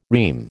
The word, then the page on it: REME: American English